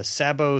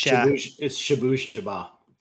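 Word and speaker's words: Shabooh Shoobah: And it's interesting to look at this list because INXS 1980, Underneath the Colors 1981, is it Sabo? I don't know how you pronounce that. – It's Shabushaba.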